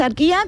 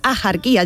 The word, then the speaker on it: Axarquía: Escribimos Axarquía con X, sí. ¿Vale? Pero ¿cómo lo pronunciamos? ¿Axarquía? No, yo no conozco a nadie, a mí que me registren, pero yo no conozco a nadie que diga Axarquía.